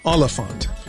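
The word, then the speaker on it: Olyphant: Yeah, that's how it's pronounced. Oliphant.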